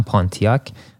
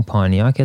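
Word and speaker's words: Pontiac: فکر کنم طلب ایرانی ها میگن پانتیاک ولی همون پانیاک تلفظ درستش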